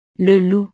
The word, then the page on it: le loup: frances